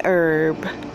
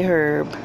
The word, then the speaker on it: herb: But as the grass and as the green herb, some say herb, I've always been taught to say herb, silent H, okay, which nobody envies nor frets at all.